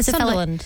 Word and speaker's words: Sunderland: Yeah, Sunderland. – Sunderland. – Yeah. – Is that a beautiful part of the UK? – It's okay. – It's okay. Okay. What is it the equivalent of? – Sunderland, maybe like a Perth.